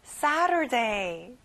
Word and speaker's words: Saturday: 앞에 새 강세를 두고요. T 발음은 안 나요. Saturday.